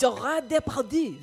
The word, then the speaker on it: Gérard Depardieu: Gérard Depardieu.